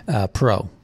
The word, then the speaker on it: Parrot: There is a story, this guy named Alan Perot, spelled Parrot, actually, P-A-R-R-O-T, but his last name's pronounced Alan Perot. Perot.